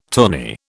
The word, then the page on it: tourney: British English